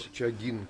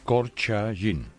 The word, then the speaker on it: Korchagin: Siempre pronuncio mal su apellido, entonces... – Korchagin, con G. – Con G. Korchagin. – Gin. – Esto sí, es. Gin. Gin. Esto es.